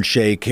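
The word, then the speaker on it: Sheik: And recognizes that racial tropes, that if you can have the iron shake and if you can have, they pronounced it chic, but I believe it should be pronounced shake,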